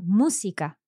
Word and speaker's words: música: So música, a third last syllable pronounced. Okay, so música, música, música. – Mm-hmm. – And that's an esdrújula. – Yes. – And there's an accent on the mu.